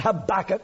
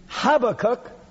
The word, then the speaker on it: Habakkuk: It's not Habakkuk. It reminds me of a tobacco maker for some reason. It's Habakkuk chapter 2 and verse 4.